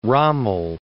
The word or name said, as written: Rommel